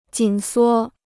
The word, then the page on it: 紧缩: jǐn suō